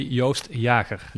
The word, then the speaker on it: Joost Jaeger: That's indeed how Americans pronounce my name. But the Dutch people say Joost Jaeger.